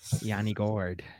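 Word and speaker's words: Yanni Gourde: Yanni Gord.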